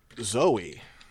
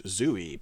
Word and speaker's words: Zoe: So we're moving on to Zoe, which looks like it should be pronounced Zooey, but that's incorrect.